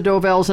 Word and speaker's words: Dovells: Del Vos. – Number six. – Del Vos. I'm yeah, not saying it right. – That's all right. You know it's the Dovells, and I'll give it to you for that.